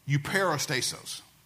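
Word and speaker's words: Euperistasos (εὐπερίστατος): It's epiristasos. Let me back up and say it again. Euperistasos. And it's a compound of three words, E-U, and then P-E-R-I, and then S-T-A-T-O-S.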